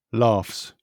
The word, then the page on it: laughs: British English